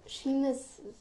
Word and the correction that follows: Seamus: Es ist richtig, aber du hast ihn falsch ausgesprochen. Du sagst Seamus Finnegan, er heißt Seamus.